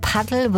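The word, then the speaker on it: Padel: Also es schreibt sich Paddle, wird aber englisch ausgesprochen.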